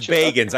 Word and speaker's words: Bagans: He says Bagans.